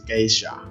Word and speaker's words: Geisha: 呃，同同样的道理，在伊索比亚就是艺的艺伎呢，呃，它是一个叫圭夏村，啊、哦，圭夏嘛，那那边的连那边的人不是你，那边的人啊，他对圭夏村的发音就是 geisha，所以那边出来的豆子都叫 geisha，啊。